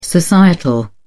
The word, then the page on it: societal: British English